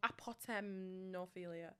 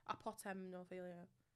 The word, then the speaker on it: apotemnophilia: Ametophilia. Ametophilia? That's sick. I'm sure that's sick. Is it? – Amet. I don't think that. No, I, oh no, I'm saying it wrong. Apotemnophilia. – Apotemnophilia.